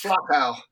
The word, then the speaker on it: Flachau: And then now we're in Flocker, how do you say it? – It's Flachau.